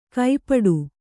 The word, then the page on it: ಕೈಪಾಡು: kai pāḍu